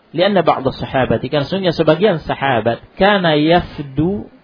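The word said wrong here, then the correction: يَفِدُ: kana yafidu, ini bacanya kana ya yafidu, wufud, ini wufud, nah wufud yafidu, kana yafidu ala nabi sallallahu alaihi wasallam.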